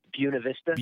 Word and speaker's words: Buena Vista: Well, hey, if you guys are listening, just tuning in, we have the owner of Deerhammer Whiskey from how do you pronounce that? – You're close. Buena Vista.